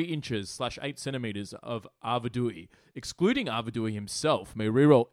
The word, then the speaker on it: Arvedui: Is that how you pronounce it? – Arvedui. – Yeah, no, there's no N. – There's definitely no N.